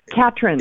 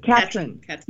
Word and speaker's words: Katrin: Catherine. – Is it Catherine? – Catherine. You said it right the first time.